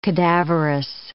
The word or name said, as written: cadaverous